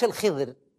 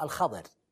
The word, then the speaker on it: الخضر: هو الخضر اسمه الخضر مش الخضر زي ما تنطقون، لا بفتح الخاء الخضر.